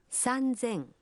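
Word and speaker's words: Санзэн (さんぜん): Но и здесь произношение меняется. 3000 будет звучать как… Санзэн.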